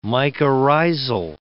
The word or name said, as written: mycorrhizal